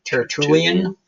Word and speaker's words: Tertullian: Tertullian.